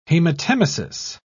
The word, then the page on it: hematemesis: British English